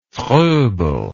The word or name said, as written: Froebelism